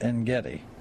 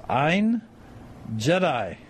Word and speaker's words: En Gedi: That's not En Gedi. – Uh-huh. – You'll recognize it when I pronounce it like this. Ein Jedi.